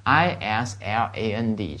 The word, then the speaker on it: island: 那么这个地方的话，日本由四个离岸亚洲海岸之外的大岛组成，这个 island 这个词一定注意一下， island 是吧， s 是不发音的，注意一下，跟我读一下吗， island， island。